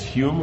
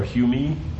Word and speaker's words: Hume: How do you say this? Hume or Humey? – Hume. – Very Hume. – Hume.